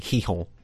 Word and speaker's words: Gijón: it's spelt G-I-J-O-N, but it's pronounced Sporting Gijón.